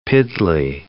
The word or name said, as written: piddly